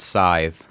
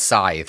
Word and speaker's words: Scythe: If there was no E at the end, maybe you could pronounce it Scythe. – So this is, Devin might correct me. Devin learned, he's doing professional development today about reading and teaching phonics skills. This is called the magic E, which changes the magic E.